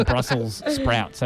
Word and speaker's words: Brussels sprouts: I don't know if you watched the news last week, but everyone's been pronouncing Brussels sprouts. So Brussels ends with an S.